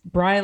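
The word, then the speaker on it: Brey: It looks like Bray because it's B-R-E-Y, which looks like it should rhyme with gray. So what I always say is it's Bry like try, don't ask me why.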